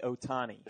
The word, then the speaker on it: Ohtani: Shohei Ohtani.